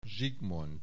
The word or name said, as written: Zsigmond